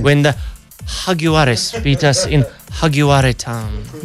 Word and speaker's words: Jaguares: Or as our sports people say, the Jaguares. It's a soft J, silent J. Jaguares. – Like hug. Jaguares.